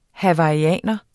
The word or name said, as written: hawaiianer